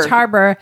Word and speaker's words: Roche Harbor: Is that how you say it? – Roche Harbor. – Roche Harbor? – Mm-hmm. – Not Roche. Roche. Like, yeah. – You're not having a... – Roach. Richard Roach. – No.